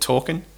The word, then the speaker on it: TORQN: And so, later that year, we stood up a company called Talkin, and we spent a couple of years building an app.